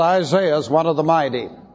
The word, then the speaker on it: Isaiah: Turn to the book of Isaiah. We're interested in chapter 3, but let's go to verse 1 to see how it all gets started. Isaiah, or as the British say, Isaiah.